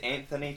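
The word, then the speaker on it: Anthonie: So my name is Anthony Tonin. It's A-N-T-H-O-N-I-E. And then Tonin, T-O-N-N-O-N.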